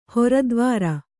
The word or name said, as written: ಹರಿದ್ವಾರ